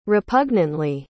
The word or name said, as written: repugnantly